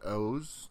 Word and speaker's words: OOO: It's written O-O-O, but it's pronounced O's. Don't ask us why.